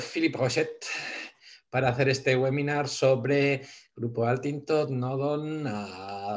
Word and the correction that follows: Altior: Grupo Altior, Altior. – Altior. ¿Por qué digo Altintot? Altior.